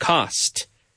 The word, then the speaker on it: cost: Notice the pronunciation of the word cost. Cos. We don't say cos. We say cost. You have to pronounce the T.